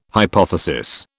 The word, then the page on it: hypothesis: British English